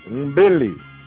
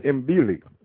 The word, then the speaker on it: mbili: A lot of these with the NDO, and you see this, like the little letter before it, it's actually pronounced, but then it's not. It's still silent. So as in this, Mbili. Mbili. M-B-I-L-I. It's almost like they're just saying Billy. Billy. Is 2. Mbili. 2. M-B-I-L-I.